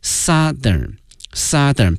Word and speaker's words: Southern: 但是啊同学们记住了，同时啊我们原来已经讲过南方这个词 south 如果说变成形容词之后呢，对它的这个发音会有一定的影响，从原来的大口型这个 south 变成了 Southern 变成小口型的一个啊，从原来那个凹音变成了小口型的一个啊 Southern，然后我们加一个同样是一个最高级 most， the southernmost city。